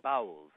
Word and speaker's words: Bauls: There is one of the stories, the last story in the book, is a story about the bowels. That's spelled B-A-U-L-S. That's nothing to do with internal waterworks.